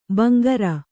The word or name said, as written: ಬಂಗಾರ